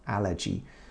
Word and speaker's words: allergy: You know, Japanese people say allergy. Actually, the pronunciation is allergy, soft G, allergy.